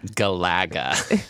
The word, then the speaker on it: Galaga: It's pronounced Galaga.